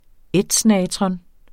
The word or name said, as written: ætsnatron